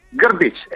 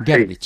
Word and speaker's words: Grbić: Oye, queremos preguntarte lo primero, ¿cómo se dice? Grbić, porque tiene más consonantes que vocales. A ver, a ver. – Grbić, exacto. – Grbić, sí.